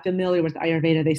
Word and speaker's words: Ayurveda: Yeah, so basically Ayurveda, it's a Sanskrit word. So a good way to think of pronouncing it is capital letter R, capital letter U, Veda.